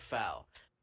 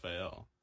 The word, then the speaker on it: FAL: And then I called and was like, AL, it's not pronounced the foul. And then I think you argued about it a little bit. And then Hannah in the comments of that podcast episode agreed with him. And, "I love you." and I said, "Bruh." And then after that, I went and looked it up, and I'm right. It's supposed to be pronounced. – Oh no, it's absolutely supposed to be pronounced FAL, like in real life.